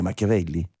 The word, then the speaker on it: Machiavelli: Ho detto bene, Machiavelli? – Ha detto molto bene, ha detto molto molto bene.